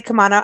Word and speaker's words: Camano: But last time she was on the show, I remember saying Camano. You say Camano, I say Camano. And I want to make sure I'm saying it correctly. So welcome back, Sherry. How are you? – I'm doing great. And it is, you said it right.